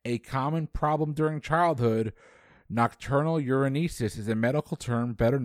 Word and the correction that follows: enuresis: That is correct, and I mispronounced that. It's enuresis. I apologize. You got it anyway. A common site for piercings, the auricle. A-U-R-I-C-L-E is a cartilaginous region known for what part of the body? – I'm just going to say the earlobe.